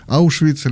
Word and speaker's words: Аушвиц: В этот день был освобожден Аушвиц или Освенцим в нашем произношении.